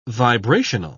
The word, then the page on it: vibrational: British English